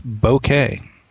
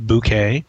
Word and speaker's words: bokeh: Pronunciation of it, he says, is bokeh, with the W in bow being nearly silent. So it's definitely not pronounced bouquet. Doesn't rhyme with poke, doesn't rhyme with boca. Well, I've been saying boca, so I guess that's where I went off the rails with that.